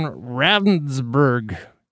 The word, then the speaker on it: Ravnsborg: I want to talk about this Jason Ravnsborg young man, I believe. R-A-V-N-S-B-O-R-G.